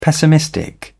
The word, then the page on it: pessimistic: British English